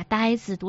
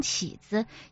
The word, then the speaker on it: 呆子: 钟书在家里已开始囫囵吞枣地阅读这类小说，把呆子读成起子，也不知《西游记》里的呆子就是猪八戒。